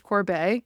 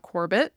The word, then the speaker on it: Corbet: I personally don't feel it, but Brady Corbet, just learned that it's pronounced Corbet, not Corbett, like I've been saying for years now.